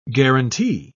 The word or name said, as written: guarantee